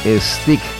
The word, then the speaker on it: Styx: Banda que, recuerdo, es originaria de esas latitudes, Stick. La banda, ahora sí, lo digo bien, fundada por los hermanos Panozzo junto a Dennis DeYoung.